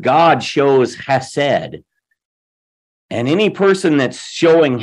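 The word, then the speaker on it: chesed (חסד): That Hebrew word is chesed, and I'm purposely trying to do the chesed. Sometimes it's spelled H-E-S-E-D, but a lot of times you see it with a C-H-E-S-E-D because it's, you know, they use the term guttural. Hebrew has that kind of thing. Chesed means kindness.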